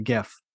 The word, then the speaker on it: GIF: It's supposed to be pronounced JIF, but they say it's actually GIF.